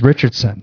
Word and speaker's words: Richardson: Yeah, so when I started first doing open mics, my last name is Richardson. It's not very hard, but it's a lot of syllables. So the first guy would host would say, "Keep it going for Joel Richards."